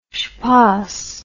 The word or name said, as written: shpos